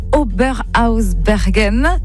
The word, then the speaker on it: Oberhausbergen: Vous avez donc tourné en Alsace, notamment à Oberhausbergen.